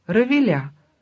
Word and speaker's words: Равиля: Это она, Раиса, Равиля. Ударение на последнем слоге.